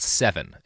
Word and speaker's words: Sevin: pesticide called Seven, and it's spelled S-E-V-I-N.